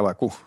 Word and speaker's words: Baqú: ¿Bakú se pronuncia? – Bakú, sí. sí.